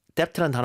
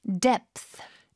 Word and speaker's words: depth: 자, depth라는 단어 발음. – depth. – 이게 이제 depth, p 발음을 해줬다가 th로 가야 되거든요.